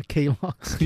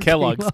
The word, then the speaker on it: Kellogg's: A K-Logs. – Yeah, not Kellogg's. K-Logs. You never seen the video? He no, calls it K-Logs.